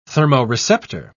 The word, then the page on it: thermoreceptor: British English